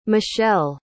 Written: michell

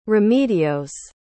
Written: remedios

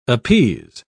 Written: appease

